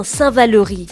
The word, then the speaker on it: Saint-Valery: On prononce bien Saint-Valery et pas Saint-Valery.